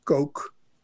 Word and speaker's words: Koch: Die naam wordt nu uitgesproken als Coke, als in Coca-Cola. Eh,